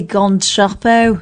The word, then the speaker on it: Gone Troppo: You're saying it wrong, by the way. It's obviously Gone Troppo.